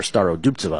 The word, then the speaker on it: Starodubtseva: Maya Joint plus 150 against Yulia Starodubtseva, or Starodubtseva, I believe it's pronounced, from Ukraine.